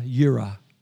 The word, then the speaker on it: Jireh: I know, because in English we look at the J and we pronounce Jehovah, but it's actually pronounced with a Y. Jehovah Yireh.